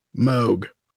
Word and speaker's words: Moog: Long O rhymes with rogue. Moog.